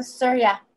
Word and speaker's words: Surya: She practices how do you say this, Surya meditation? – Oh, Surya.